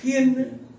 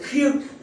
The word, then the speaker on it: khiên: Cho nên ở cái kinh chúng ta đọc ở Bắc là xin Chúa tha tội tội khiên, xin tha tội khiên. Thì trong Nam anh không đọc là tội khiên đâu, xin ta tội khiêng nặng quá, xin tha tội khiêng của chúng con.